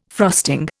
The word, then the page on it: frosting: Indian